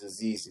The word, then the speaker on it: Azeeze: I have people look at my name as Azeez. It's easy to pronounce, right? And it'll be like Azeez. Am I saying it right? Why would you not be saying it right? It's a five-letter word.